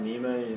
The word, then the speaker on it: Nima: I'm Ashkon Nima. You can spell that A-S-H-K-O-N, last name is N-I-M-A.